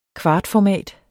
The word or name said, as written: kvartformat